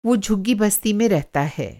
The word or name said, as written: झुग्गी बस्ती